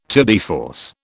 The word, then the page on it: tour de force: British English